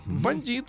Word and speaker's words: бандит: В 1866-м Александр Ильич Ульянов, старший брат Ленина, который неудачно покушался на императора Бандист. Александра II. Бандит, сам правильно говорить, бандит.